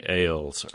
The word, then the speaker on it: Ailes: And by the way, I apologize for mispronouncing Ailes earlier in the newscast.